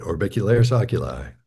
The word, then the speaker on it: orbicularis oculi: Is it the orbicularis Yes. – oculi muscles? – Yeah, you nailed it. Orbicularis oculi.